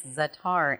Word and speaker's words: za'atar: And again, it's za'atar, and it's spelled Z-A-A-T-A-R.